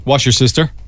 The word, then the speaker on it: Worcestershire: Worcestershire in the UK. – Wash your sister. – Indeed. Several syllables getting swallowed up in English.